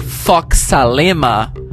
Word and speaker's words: Fox Salema: outside Essa foi a Fox Salerma, ou desculpa, Fox Salema. Falei certo, né, Fox?